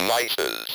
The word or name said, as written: mice's